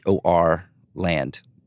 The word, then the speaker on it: Haaland: They're spelling it, his name is spelled Haaland. They're spelling the pronunciation guide Horland. So I'm guessing that's Horland.